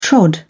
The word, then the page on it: trod: British English